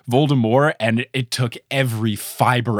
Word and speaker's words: Voldemort: Look, all of those people that do that are the same people that pronounce Voldemort Voldemort, which I know that it's French or whatever, so the T is silent, but look, in every single movie, they say Voldemort, and I know that in the first couple of audiobooks, they don't say the T, but J.K.